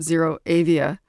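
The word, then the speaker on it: ZeroAvia: Zero Avia.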